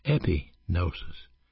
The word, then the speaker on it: epignosis: The Greeks had a word for knowledge, and that word was gnosis, G-N-O-S-I-S. But there was another word which was much more important, and it's pronounced epignosis, E-P-I-G-N-O-S-I-S.